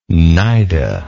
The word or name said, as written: cnida